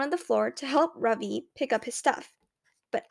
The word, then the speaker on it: Ravi: Mrs. Beam has been calling him Ravi, but when he introduced himself earlier, I'm pretty sure he said his name was Ravi, with the accent on the second symbol, syllable.